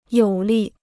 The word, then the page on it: 有力: yǒu lì